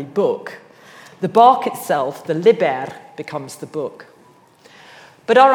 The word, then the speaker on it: liber: Its root is liber, with a short I, the Latin term for the skin, bark, or rind of plants.